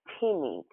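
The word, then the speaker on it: timi (τιμή): But in the Greek, the word honor is pronounced timi, T-I-M-I.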